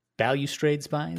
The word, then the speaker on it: Balustrade Spy: um, but is it is it balustrade spy? Is that how you say it? – Balustrade spy is what I'm yeah.